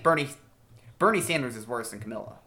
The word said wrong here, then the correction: Kamala: Yeah, people get mad if you mispronounce her name, so it's Kamala.